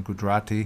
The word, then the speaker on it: Gujarati: And obviously, you know, we're once we have people who speak Hindi and Gujarati, did I say that properly? – You said that right.